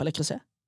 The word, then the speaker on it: Le Creuset: Grei uttale på Le Creuset? – Ja. – ja. – Det er grei, ja. – ja. Det er sånn det sies.